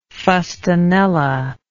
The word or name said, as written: fustanella